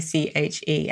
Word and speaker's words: synecdoche: Sorry, I'm laughing because I mispronounced this earlier. So you're hearing the edited version. So it's synecdoche.